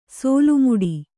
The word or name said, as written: ಸೋಲುಮುಡಿ